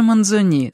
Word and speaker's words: Манзони: А поэт Манзони, лучше даже Манзони, с ударением на последнем слоге,